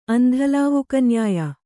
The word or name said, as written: ಅಂಧಲಾವುಕನ್ಯಾಯ